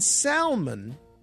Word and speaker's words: salmon: The L in salmon is silent.